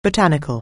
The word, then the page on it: botanic(al): anglais